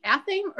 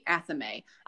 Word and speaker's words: athame: It is spelled, athame is spelled A-T-H-A-M-E. So it could be athame or it could be athame. – I've always heard athame. – People pronounce it both ways, and there is not a problem with either way.